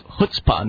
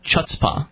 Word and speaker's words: chutzpah: I actually mispronounced a word. It was chutzpah, not chutzpah. – Did you say chutzpah? – Yeah. – Earlier tonight? – No, it was last night. – Okay. – Well, how was I supposed to know this? C-H is ch-ch. – I guess. – I didn't know it was an H sound.